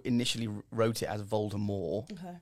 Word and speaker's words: Voldemort: Voldemort is pronounced Voldemort. – Yes.